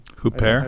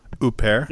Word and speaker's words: Huppert: Huppert. – I've always been off about it. – Huppert.